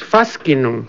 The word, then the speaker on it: Fascinum: fast Facinium. No, no. – Este, en latín la pronunciación es fascinum.